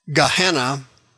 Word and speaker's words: Gehenna: Gehenna is pronounced in English, Gehenna, that's the lake of fire.